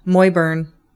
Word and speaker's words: muirburn: This next one I'm going to do is Moyburn. – Yes. – It's spelled Muirburn.